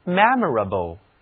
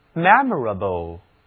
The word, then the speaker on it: memorable: 让我给词根 m-e-m-o-r memor 后面加上形容词后缀 a-b-l-e able，读作 memorable。 memorable 这就是值得纪念的，难忘的。 The memorable childhood， The memorable childhood，难忘的童年时光。在这个单词当中有三个元音，字母 e 读作扁口的梅花音 a，字母 o 以及 a 它们都读作倒写的 a。 memorable。好，我们给 m-e-m-o-r 这个词根加上另外一个形容词后缀 i-a-l 组成的词读作 memorial。